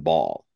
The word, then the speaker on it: Baal: So Ball, B-A-A-L. Is that pronounced Ball? – B-A-L-L? – B-A-A-L. – When you say, yes, Ball, yeah.